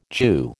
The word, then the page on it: Jew: American English